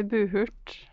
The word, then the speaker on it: Buhurt: Buhurt. Bare tenk på butthurt uten T. – Ja, Behørt. Det, det er forklart.